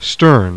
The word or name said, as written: STERN